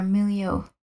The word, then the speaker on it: milieu: I think it's our milieu. – Our milieu. – Oh, did I mispronounce it? – No, you were totally right.